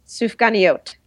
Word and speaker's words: Sufganiyot: Sufganiyot.